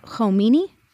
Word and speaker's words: Khomeini: So then what are the contributing factors behind the rise of the Ayatollah Khomeini? – Yes.